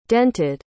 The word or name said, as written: dented